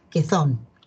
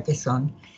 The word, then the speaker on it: Quezón: En Filipinas pasa que Filipinas tenía un presidente que tenía un apellido que me causa un poco de gracia porque, bueno, en España sería Quezón. Para nosotros que no pronunciamos la Z sería Quezón.